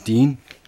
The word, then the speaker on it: Dean: My name is Dean, D-E-A-N, Hersel, H-E-R-S-E-L. – H-E-R-S-E-L? – E-L.